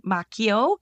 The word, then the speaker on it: Macchio: November 4th, also Ralph Macchio, which is actually I'm going to pronounce it correctly, Macchio, because it's an Italian last name. So I knew it. That's why I let you do that. – The K sound.